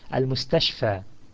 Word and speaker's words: المستشفى: So it ends with a long fatha vowel sound, al-mustashfa.